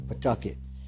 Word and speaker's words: Pawtucket: They kind of make a rush of that first syllable, and they de-emphasize the W. So you want to make it Pawtucket.